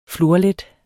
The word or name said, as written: FLORLET